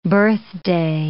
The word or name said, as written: birthday